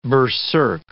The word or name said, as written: berserk